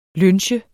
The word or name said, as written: lynche